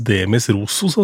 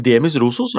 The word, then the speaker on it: Demis Roussos: Eh, vi hadde jo faktisk Jeg tror jammen navnet uttales Demis Rosos. Altså. Demis Rosos, ja.